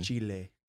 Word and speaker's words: Chile: C-H-I-L-E. I'll give you a moment to think about it. If you said Chile, you are incorrect. Apparently, it's pronounced chile.